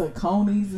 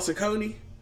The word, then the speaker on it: Saucony: You know it's not called Saucony? The name of the company is not Saucony. – How do you pronounce it? – Saucony. – Nope. Niggas gonna always say Saucony.